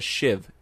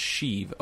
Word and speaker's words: sheave: everybody calls them a shiv. It's pronounced sheave, okay? It's a sheave. It's not a shiv.